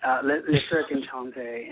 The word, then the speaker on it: Le Cirque Enchanté: Tell us about the inspiration behind Le Cirque Enchanté. Did I pronounce that right? – Absolutely. Le Cirque Enchanté.